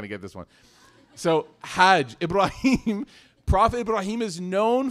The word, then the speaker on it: Hajj: What action, what pillar of our faith rhymes with fudge? Hajj, right?